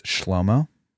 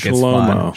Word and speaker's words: Shlomo: Solomon's name in Hebrew is pronounced Shlomo. I think Shlomo. It's fun. Shlomo. – That is fun. – Yeah. Shlomo. Shlomo. It's from the root that we get the word shalom.